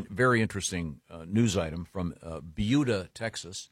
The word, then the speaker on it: Buda: No, that's how it's pronounced. – Okay. – Well, Jason, is it B-U-D-A? – Can you yes.